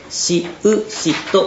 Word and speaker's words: 四大人: 四大人と書いて詩詩氏と呼びます。